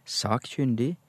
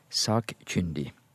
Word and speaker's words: sakkyndig: Men på, på bokmål så må vi nok godta trykket både på førstestaving, sakkyndig, eller sakkyndig. Det tror jeg nok vi må si. Altså en NRK-journalist kan uttale dette på begge måter, men bør helst være konsekvent, altså ikke skifte i et innslag.